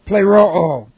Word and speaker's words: pleroo: We have the perfect active indicative of pleroo. And pleroo here, that's P-L long E-R-O long O. Pleroo.